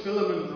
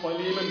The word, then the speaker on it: Philemon: So I don't want to speak from the book of, I always called it Philemon, right? But as I've listened to stuff this week, it's pronounced Philemon, but I'll probably say Philemon, Philemon, loads of different ways during the sermon as I forget how it's pronounced.